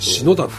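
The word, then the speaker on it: 信太: はあ、信じるに太いっていう字で篠田って読ませるんです。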